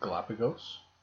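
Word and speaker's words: Galápagos: Is that how you say it? – Galapagos? – Galapagos Island, in a remote area on the island.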